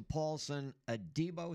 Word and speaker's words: Paulson Adebo: Congratulations to Paulson Adebo.